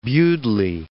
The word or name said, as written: Baldwin